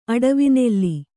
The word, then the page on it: ಅಡವಿನೆಲ್ಲಿ: aḍavinelli